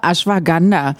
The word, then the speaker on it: Ashwagandha: Ashwagandha.